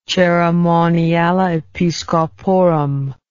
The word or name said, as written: Caeremoniale Episcoporum